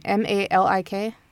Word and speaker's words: Malik: So in January of 2017, Stephen Malik, I think that's how you say his name, M A L I K. – Yeah, it's Malik.